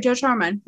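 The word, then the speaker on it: Joe Trohman: They announced a new collaboration on a new comic book series, which has been co-created by, and you probably know how to say this guy's name. Is it Joe Troman? He's the Fall Out Boy guitarist. – Oh, yeah. Yeah, you're right. Joe Troman.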